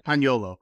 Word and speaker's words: Paniolo: How do you say the dude's name? – Like, it's Paniola. – Paniolo. Yeah. So Paniolo's there too.